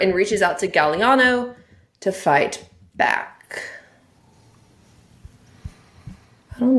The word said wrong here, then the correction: Galino: So, a Cammy Galino, I think it's Galino. Sorry. I've been pronouncing it wrong.